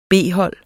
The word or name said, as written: B-hold